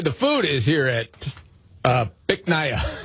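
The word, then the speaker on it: Bich Nga: which he was going to have a little fun with, because the name of the restaurant, you pronounce it Bic Nia. – Bic Nia? – Yes, but it's spelled B-I-C-H, one word. Yeah. Second word, N-G-A.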